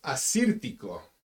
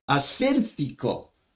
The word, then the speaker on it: Assyrtiko: Teddy, how do you pronounce the grape? – Pronounce Assyrtiko. – Assyrtiko. I like that.